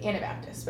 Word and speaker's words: Anabaptist: A-N-A? – Yes. – Right? – I may have said that pronunciation incorrectly. Anabaptist, but yes. – Okay. – It's A-N-A Baptist. Anabaptist.